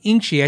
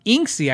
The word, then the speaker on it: Inchi: I gotta say Inchi. I told you phonetically. If I read Go Godin, I or Godin, I say Godin. If I see Inksy, I say Inksy, but it's Inchi guitars.